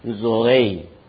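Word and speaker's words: zoe (ζωή): 那个原文是一个词，是名词，那个名词的发音是 zoe。主活着我们也活着是 zo，我们得到永远的生命是 zoe，一个词。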